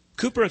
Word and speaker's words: Cowper: William Cooper, and that's spelled C-O-W-P-E-R, but it's pronounced Cooper.